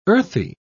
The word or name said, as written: earthly